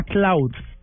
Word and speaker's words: Klout: Pues eh, hoy traigo, digámoslo como para ser puntual, una, un concepto que se llama cloud, que es K-L-O-U-T. – Sí, porque una cosa es cloud y otra cosa es cloud. – Cloud. Lo que pasa es que la pronunciación es, es muy es difícil hacer esa, esa pronunciación, pero es Cloud con T y con K,